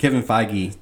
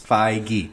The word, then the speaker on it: Feige: I can never say this guy's name correctly. Kevin Feige. – Two syllables. – Feige.